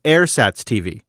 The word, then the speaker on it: Ersatz: The Ur in Ersatz TV is pronounced like air because it's German. So it's Ersatz TV.